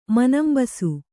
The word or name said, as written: ಮನಂಬಸು